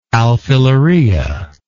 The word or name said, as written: alfilaria